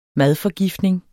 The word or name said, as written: MADFORGIFTNING